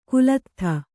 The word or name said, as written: ಕಾಲದೂತ